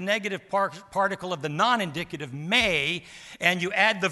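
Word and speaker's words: may (μή): And the negative particle of the non-indicative moods is may. May, we would say, M with a long A.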